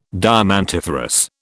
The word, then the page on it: diamantiferous: British English